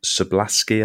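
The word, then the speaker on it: Szoboszlai: Is it Sablaski? I think that's how you pronounce it. – Yeah.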